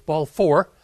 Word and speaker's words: Balfour: Now, this Balfour, I never know how to say it right. I want to get an R in there. So it's Balfour letter.